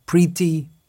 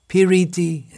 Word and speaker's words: piriti: Priti, piriti in three syllables.